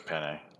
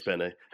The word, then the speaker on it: Penne: I hope it's Penne. That's how you say it. – It is Penne.